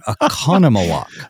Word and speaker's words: Oconomowoc: I think I might have butchered the name of your town, Oconomowoc. – It is Oconomowoc, yeah.